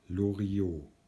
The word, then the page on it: Loriot: German